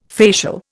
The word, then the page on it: facial: Indian